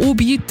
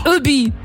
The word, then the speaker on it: Obi: Und das ist keine Abkürzung, aber Obi, das ist einfach nur Hobby auf Französisch ausgesprochen. Obi.